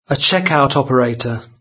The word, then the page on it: checkout operator: British English